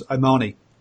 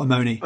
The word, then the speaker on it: Omani: Omani, not Omoni. – Oman. – Omani. Yeah, it's not how it's spelt.